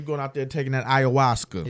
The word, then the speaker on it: ayahuasca: Ahuska. – Ayahuasca.